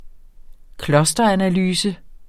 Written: clusteranalyse

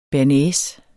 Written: bearnaise

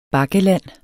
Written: bakkeland

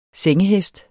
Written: sengehest

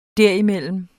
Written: derimellem

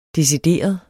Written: decideret

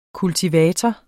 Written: kultivator

